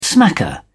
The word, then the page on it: smacker: British English